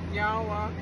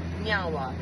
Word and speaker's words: nyawa: So in our language, we say nyawa, which means thank you. – Nyawa. – Nyawa. – I said it right? – Yeah, you did. – Nyawa.